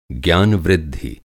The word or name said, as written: ज्ञान-वृद्धि